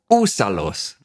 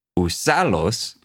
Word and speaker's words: úsalos: Sí, como úsalos, ¿no? Uh-huh. Está ahí en el inicio, no es usalos.